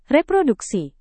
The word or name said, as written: reproduksi